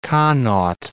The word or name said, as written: Connacht